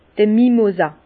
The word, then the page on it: d'r Mimosa: Bas Rhin; Herrlisheim